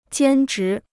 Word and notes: 兼职: jiān zhí

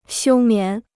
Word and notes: 休眠: xiū mián

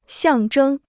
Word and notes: 象征: xiàng zhēng

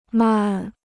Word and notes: 漫: màn

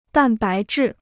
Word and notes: 蛋白质: dàn bái zhì

